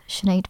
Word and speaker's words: Sinead: So you may find me on the internet under the handle of The Sinead Burke. My name is spelled S-I-N-E-A-D.